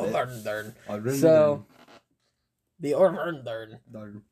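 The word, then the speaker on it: Örvar-Odds: Did I pronounce that right? – It's pretty close. – Pretty close? Okay, what's the... – Orvar Odar. There you go.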